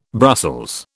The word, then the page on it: Brussels: British English